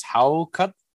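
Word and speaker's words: hákarl: Well, all right, so let's get the pronunciation one more time. It's Haukarl. – Yeah, I don't think I can make that pronunciation. – Haukarl. Haukarl. Oh, that was close. – So there's like a almost an R sound in there.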